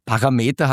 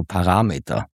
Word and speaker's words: Parameter: Parameter hat nichts mit Millimeter zu tun und hat die Betonung auf dem zweiten A, also Parameter. Und ich habe offenbar in einer Episode Parameter gesagt.